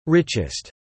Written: richest

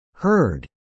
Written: Herd

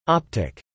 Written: optic

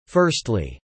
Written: firstly

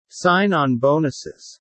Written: sign-on bonuses

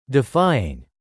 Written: defying

